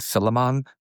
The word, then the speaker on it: Philemon: It may be written Philemon, but the way you pronounce it is Philemon.